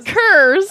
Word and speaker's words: curs: You scurvy curds. – Curds? It's curs.